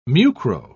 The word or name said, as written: mucro